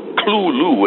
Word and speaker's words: Cthulhu: Well, the pronunciation you just gave has become pretty standard, but Lovecraft declares in a letter of 1934 to some friend of his that the pronunciation is actually only two syllables. The T-H is not pronounced as a T-H, it's pronounced as a kind of guttural L. He actually renders it as C-L-U-L-U. Clue-loo, and you have to sort of cough or bark it out.